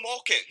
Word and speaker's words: Malkin: Michelle Malkin? – Michelle Malkin.